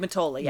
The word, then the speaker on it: Mottola: Greg Mottolo, is that how you say his name? He directed Greg Mottola, yeah. Mottola.